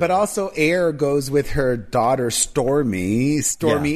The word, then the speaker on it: Aire: It's spelled A-I-R-E. And Kylie clarified that A-I-R-E is pronounced air, which is fine. It could have a silent E.